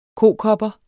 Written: kokopper

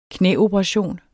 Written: knæoperation

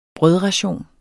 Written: brødration

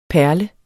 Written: perle

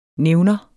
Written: nævner

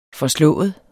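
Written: FORSLÅET

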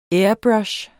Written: airbrush

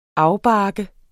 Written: afbarke

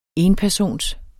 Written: enpersons

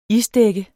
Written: isdækket

